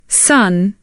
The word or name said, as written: son